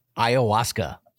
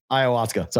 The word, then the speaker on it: ayahuasca: How do you say the word again? Hayasaka? – Hayuska. – Hayuska. – Ayahuasca. – Ayahuasca. Sorry.